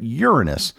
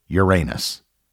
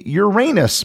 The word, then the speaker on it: Uranus: If you poll astronomers, they will almost universally tell you that the name of the seventh planet is Uranus. Likewise, if you check various dictionaries and pronunciation guides, they will also confirm that Uranus is how the planet is pronounced. Therefore, for the remainder of this episode, that is what I will be calling it, even though I know many of you probably pronounce it Uranus. The Uranus pronunciation, of course, generates tons of giggles and puns.